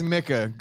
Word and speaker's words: Micah: He still says Micah. Good for Jimmy, bro. – That's what his name is. That's how you say it.